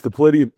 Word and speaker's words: Dipylidium: Is it Dipylidium? – It's Diplidium caninum. Don't look at how it's spelled. Just just memorize it.